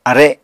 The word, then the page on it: are: Apatani